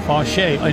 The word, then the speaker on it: Faucher: It'll be Calvin Faucher. F-A-U-C-H-E-R. Faucher. In northern France, it's pronounced Faucher.